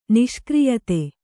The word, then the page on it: ನಿಷ್ಕ್ರಿಯತೆ: Kannada